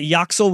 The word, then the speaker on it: Yaxel: Is that Yoxel with a J, or is it like a Y? Of course it is. – Y-A-X-E-L. – Not the soft J.